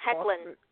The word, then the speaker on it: Hoechlin: Hoekler, is that his name? Hoekler. How's it pronounced? Hoechlin. Yeah. – Hoechlin. Hoechlin. Pardon me. Hoechlin.